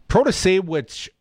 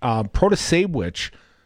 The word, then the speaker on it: Protasiewicz: I don't think that they see the Protasiewicz, pronounced like witch, Protasiewicz, is, if anything, is probably the party choice.